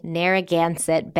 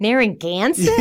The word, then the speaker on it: Narragansett: Narragansett Bay. – I got my Rhode Island accent. I was like, Narragansett? I have to say it like that? Narragansett? – Narragansett. And by the way, now it's coming back to me.